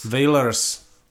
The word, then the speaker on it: Wailers: Eh, kdo ještě, kdo ještě zemřel, tak zemřel eh, Bunny Wailer, z, vlastně z toho původního vokálního tria, tria já nevím, jak to vyslovuje, Wailers, Wailers. – Wailers. – Wailers. Přesně tak, dobře jste řekl.